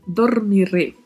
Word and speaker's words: dormiré: Dormiré. Atenção à pronúncia, tá? O O fechado, o R com vibrato e a letra E também fechada ao final.